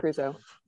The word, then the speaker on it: Creuzot: Um, you know, Creuzot, right? Is that how you say his name? – Yeah, John right? Creuzot.